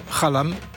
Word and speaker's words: Xalam: Avant de connaître Youssou N'Dour, la première personne sénégalaise que j'ai rencontrée, c'était Prosper Niang, qui était l'un des fondateurs du groupe Khalam. Ça s'écrit Xalam, X-A-L-A-M.